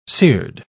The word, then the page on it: cered: British English